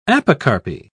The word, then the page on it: apocarpy: British English